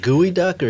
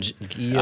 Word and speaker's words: geoduck: Is it gooey duck or geoduck? – I don't know. Gooey duck.